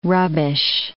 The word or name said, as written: rubbish